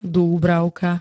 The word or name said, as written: dúbravka